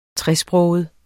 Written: tresproget

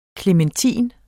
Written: klementin

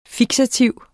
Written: fiksativ

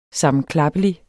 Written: sammenklappelig

